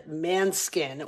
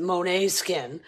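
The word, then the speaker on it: Måneskin: The newer generation would know the band Manskin, or as they pronounce it, Monet Skin.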